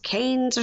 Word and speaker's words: Cairns: They go to Cairns, but it's like pronounced Canes or something.